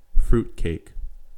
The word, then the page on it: fruitcake: American English